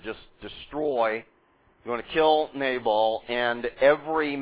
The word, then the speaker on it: Nabal: You will remember that David asked help from Nabal, as you're used to probably pronouncing it. Nabal is how you would say it in Hebrew. And Nabal does not give his consent.